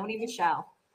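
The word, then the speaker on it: Sony Michel: I pronounce his last name Sony Michelle, but is it Michelle? Okay. I get nervous sometimes. – How else would you say it? – I don't know. Like Michelle. – You said it right.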